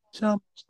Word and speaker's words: Chamo: So Chamo is spelled C H A M O. Chamo. It, it's pretty much pronounced the way it looks.